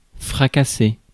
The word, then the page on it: dash: /dæʃ/